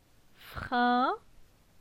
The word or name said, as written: Frin